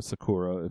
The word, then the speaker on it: Sakura: Sakura, or Sakura, if you are being proper about your Japanese pronunciation, ended up losing the lip sync of S&M by Rihanna and is our most recent queen to go away.